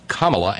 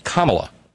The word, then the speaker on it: Kamala: My name is pronounced Kamala. And she even phonetically spelled it for the maggots and whatnot out there. C-O-M-M-A hyphen L-A. Kamala.